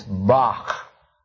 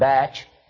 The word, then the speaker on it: Bach: But you've all used it, if you pronounce Johann Sebastian's name correctly, in German, it is Bach. It is not batch. It is Bach. It isn't back. Or even Bach. It's Bach. Bach.